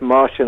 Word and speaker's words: Marcin: It's spelled Marcin, but it's pronounced Martian, apparently.